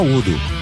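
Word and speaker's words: U.D.O: Então você tem que pronunciar o nome das letras, e o Joe, tá? Para ser chato, pode falar Udo, tá?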